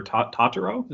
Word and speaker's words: Totoro: Pianist, In the Name of the Father, City Lights, The Life of Brian, My Neighbor Totoro.